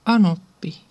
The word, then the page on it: anoppi: [ˈɑnopːi]